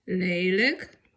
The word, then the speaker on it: Lelijk: Pamiętajmy, że L i J K na końcu wyrazu wymawiamy jako lyk. I takie słówka mamy. Frolik. – Frolik. – Aanvankelijk. – Aanvankelijk. – Kinderlijk. – Kinderlijk. – Lelijk.